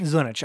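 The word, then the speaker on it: Zdunich: Zunich. I think that's pretty close. – I think the D is silent. – Yeah, it is. – Because it's ZD.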